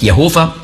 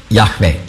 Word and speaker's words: Yahweh: Das ist also nicht Jehova, sondern Yahweh die richtige Aussprache ist. Nicht Yahweh. Yahweh ist ein hörbares H.